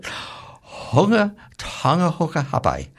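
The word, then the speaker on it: Hunga Tonga-Hunga Ha'apai: Hunga Tonga Hunga Hawaii.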